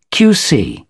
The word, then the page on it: qc: British English